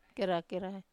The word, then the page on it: kera-kerai: wamesa